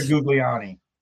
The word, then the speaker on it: Giuliani: Do you remember in 1994, the scene started to die down when Mayor Giuliani, is that how you pronounce it? Mayor Giuliani took office. – Mayor Giuliani.